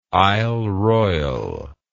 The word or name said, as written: isle royale